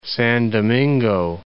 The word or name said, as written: San Domingo